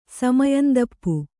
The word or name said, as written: ಸಮಯಂದಪ್ಪು